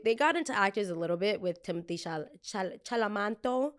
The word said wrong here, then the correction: Chalamet: or whatever, Chameleon, I don't know. Don't know how to say his last name. Very attractive man. How do you say him? Timothy Chalamet.